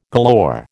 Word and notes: galore: American English

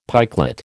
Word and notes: pikelet: American English

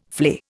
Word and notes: flay: Indian

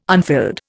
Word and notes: unfilled: Indian

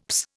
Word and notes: psst: Indian

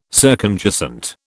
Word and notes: circumjacent: British English

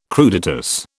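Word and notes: crudités: British English

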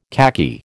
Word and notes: khaki: American English

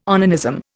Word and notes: onanism: Indian

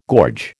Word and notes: gorge: American English

